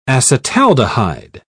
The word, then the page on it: acetaldehyde: British English